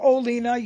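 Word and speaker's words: Ko Olina: Koolina, you got it.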